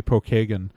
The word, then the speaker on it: Pokagon: Everyone mispronounces Pokagon State Park. It's supposed to be Pokagon.